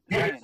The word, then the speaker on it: Zeijen: Born in Zion, is that correct? – Yeah, Zion, yes, perfect.